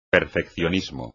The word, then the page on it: perfeccionismo: espagnol